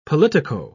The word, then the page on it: politico: British English